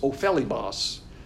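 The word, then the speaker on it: Ophelimos (ὠφέλιμος): Ophelimos. That's how you pronounce it. Ophelimos. Because the I is a double E, so it's Ophelimos.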